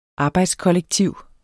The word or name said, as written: Arbejdskollektiv